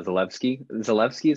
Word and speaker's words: Zalewski: I would be very interested in seeing what Nikola Zalewski is how you pronounce the W is more like a V sound with the Polish, I'm pretty sure.